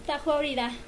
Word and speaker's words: Tahua Orira: a tu casa marearte y la siguiente participante que tenemos, ella es eh, pertenece al ballet polinesio Tahua Orira, ¿es correcta la pronunciación? – Tajua Orira. – Eh, como lo dijo ella, de la profesora Nora Ramos.